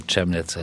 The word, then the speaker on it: Chemnitz: or Chemnitz as I think it's pronounced.